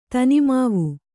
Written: ತನಿಮಾವು

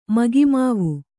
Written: ಮಗಿಮಾವು

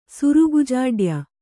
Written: ಸುರುಗುಜಾಡ್ಯ

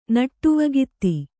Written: ನಟ್ಟುವಗಾತಿ

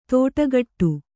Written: ತೋಟಗಟ್ಟು

